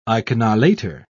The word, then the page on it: iconolater: British English